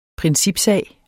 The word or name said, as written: principsag